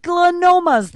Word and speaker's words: glioma: Oh, malignant gliomas. That's how you say it. Now I see it, how it's spelled, G-L-I-O-M-A.